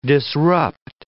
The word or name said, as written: disrupt